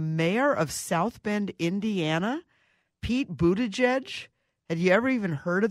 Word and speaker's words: Buttigieg: Pete Buttigieg. And I believe I'm saying that right. I studied how to pronounce his name.